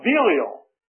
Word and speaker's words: Belial: I always said Belial, but anyway, Belial is a proper pronunciation, I understand.